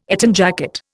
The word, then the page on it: Eton jacket: Indian